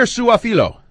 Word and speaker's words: Suafilo: The guy who may be the top guard prospect, Xavier Suafilo. Is that how you say it? – Sounds good to me.